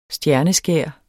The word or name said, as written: stjerneskær